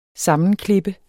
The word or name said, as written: sammenklippe